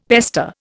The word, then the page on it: pester: Indian